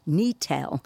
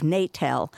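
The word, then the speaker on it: Netel: So Neet, it's spelled N-E-T-E-L. I have been told in the past that we're supposed to be saying Neetel instead of Natel, because there is another Grange called Natel, which is not that far away.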